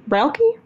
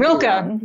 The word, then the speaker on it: Rilke: Rilke? – I thought Rilke. It was Rilke, but I could Rilke.